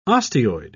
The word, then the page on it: osteoid: British English